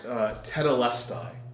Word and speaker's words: tetelestai: When Jesus said on the cross, it is finished, he used a very specific Greek word that's pronounced tetelestai. And you guys don't need to learn Greek, but that's one that's worth knowing. Tetelestai. T-E-T-L-E-S-T-A-I.